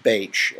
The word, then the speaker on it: Bach: By the way, that is pronounced Baitch. That was a famous British physician who used flower essences back in the day, and his name is pronounced Baitch. It looks like Bach, but it's pronounced Baitch.